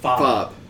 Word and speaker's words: Fabre: Archbishop Fobb. – Yes, F-A-B-R-E, and the R-E is silent.